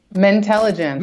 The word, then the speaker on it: Mentelligence: And I'm probably mispronouncing it. Dr. Kristen Lee, thank you so much for joining us here on the program. Tell me, how is that pronounced? – Mentelligence. – Mentelligence. Okay. Put too many syllables in there.